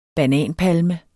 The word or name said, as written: bananpalme